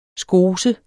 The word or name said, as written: skose